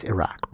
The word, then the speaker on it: Iraq: It's Iraq, bro. Like if you listen to somebody from there, they say Iraq.